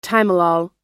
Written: timolol

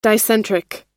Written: dicentric

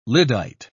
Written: lyddite

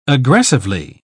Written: aggressively

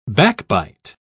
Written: backbite

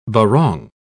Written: barong

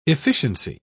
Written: efficiency

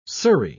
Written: Surrey

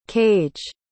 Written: kage

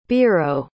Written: biro

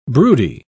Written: broody

